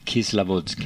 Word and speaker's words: Kislovodsk: Och Kislovodsk heter staden. – Ja visst. – Uttalar jag det rätt nu? Som ja, det gör du.